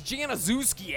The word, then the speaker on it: Januszewski: So you got to pronounce it with a soft J. I think Mike Krzyzewski does it. Januszewski. He pronounces his name Januszewski. That's it. So I finally get there. And I'm like, hey, oh, Farron. You must be Farron Januszewski. That's it's Januszewski, actually.